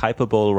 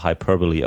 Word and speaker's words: hyperbole: Fängt im Grunde der erste Satz schon, think hyperbole rhymes with superbowl und das wird aber hyperbole ausgesprochen, was komisch ist, aber ist nun mal so.